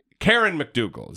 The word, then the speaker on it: Karen McDougal: So the other thing is this AMI thing with David Pecker, which we know he organized the payment from, I always get her name wrong, Karen McDougal. Is that right? – Mm-hmm.